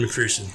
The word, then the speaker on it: McPherson: Not McPherson. Did I get the name right, Pat? – Yeah, Evan McPherson.